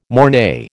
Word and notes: mornay: American English

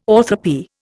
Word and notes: orthoepy: Indian

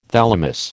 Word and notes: thalamus: American English

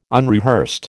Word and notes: unrehearsed: American English

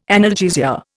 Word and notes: analgesia: Indian

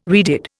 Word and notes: redid: Indian

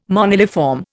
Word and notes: moniliform: Indian

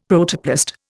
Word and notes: Protoplast: Indian

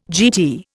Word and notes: GT: Indian

